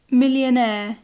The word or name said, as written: millionaire